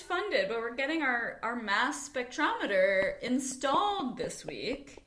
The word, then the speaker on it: Mads: So M-A-D-S, pronounced just Mass.